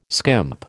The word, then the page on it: skimp: American English